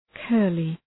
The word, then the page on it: curly: ‘kɜ:rlı